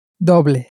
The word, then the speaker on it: doble: Now, with that in mind, that it's spelt D-O-B-L-E, how do you think you would pronounce it in Spanish? How would double be pronounced? – Doble.